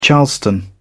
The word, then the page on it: charleston: British English